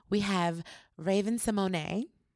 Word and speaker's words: Symoné: It's Raven Symoné. – Because she corrected us. – Absolutely. – In the most beautiful way and gave us the correct pronunciation of her name. – Yes, and it's Symoné.